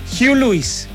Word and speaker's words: Huey Lewis: Para no equivocarme, don Miguel, Huey Lewis, ¿ve? Y dice, Huey Lewis and the News, tocando la guitarra y el saxofón un día como hoy. Entonces lo recordamos a Huey Lewis, The Power of Love, la canción de Volver al Futuro.